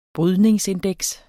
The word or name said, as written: brydningsindeks